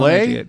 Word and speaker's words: Leigh: But you'd think a Welsh name with a silent IGH would be easy for people here. – Yeah, it's not L-E-E. – No, it's L-E-I-G-H.